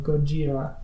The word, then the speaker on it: Gojira: But she uses the Japanese pronunciation of Gojira.